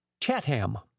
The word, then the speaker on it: Chatham: So the proper pronunciation for the town of Chatham is Chat-ham, with the accent on the hind end.